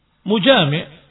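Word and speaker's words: mujami' (مجامع): Karena al-mujami', maaf, tadi bacanya salah, bukan majami', mujami', pelaku dari jima. Mujami', seorang yang berjima dengan istrinya, bersetubuh dengan istrinya.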